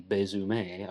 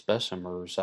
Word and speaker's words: Besumer: Then on June 28th, a baker named John Zonka made his morning call to deliver bread to the grocery of Louis Bessemer, B-E-S-U-M-E-R. And Louis Bessemer is going to be very important in this story. But some people pronounce his name Louis Bessemer, again because of the French influence of New Orleans. But Louis Bessemer's ancestry was not French, he was Polish. And most people, including Miriam C. Davis, pronounce it Bessemer. I think either one is acceptable in New Orleans.